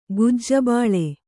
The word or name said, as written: ಗುಜ್ಜಬಾಳೆ